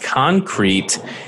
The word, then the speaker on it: Concreit: We have the CEO and co-founder of a company called Concreit. And it's not spelled like the way you think it is. It's C-O-N-C-R-E-I-T, kind of like REIT.